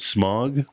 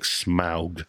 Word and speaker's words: Smaug: I pronounce it Smaug, but everybody else is like Smaug, so I always like to kind of go Smaug. This is Star Trek 2, Dark Knight Rises, no.